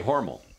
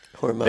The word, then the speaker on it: Hormel: Because if you're from there, like I am from there, I say Hormel, rhymes with normal. – Yeah. – But if you listen to their advertising, Hormel. They all go out of their way to pronounce it Hormel.